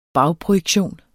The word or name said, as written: bagprojektion